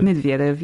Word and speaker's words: Medvedev: Medvedev, yeah.